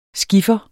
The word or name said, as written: skiffer